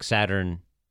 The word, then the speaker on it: Saturninus: and there is a second N in there. I had to double check it. So it's like Saturninus.